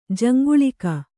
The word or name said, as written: ಜಾಂಗಲಿಕ